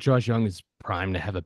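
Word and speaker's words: Josh Jung: Okay, so Josh Young, is that how it's pronounced? – Yep.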